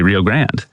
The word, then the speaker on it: Rio Grande: On the other side, you say, if you're doing it with the Anglo pronunciation of the way most Texans say it, you say Rio Grande.